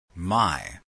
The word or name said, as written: my